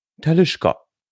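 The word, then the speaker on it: Talisker: Whisky-Destillerien korrekt ausgesprochen. Talischka. Talischka ist eine Brennerei auf der Isle of Skyr.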